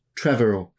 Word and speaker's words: Trevorrow: Trevorrow, cool.